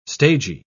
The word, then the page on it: stagey: British English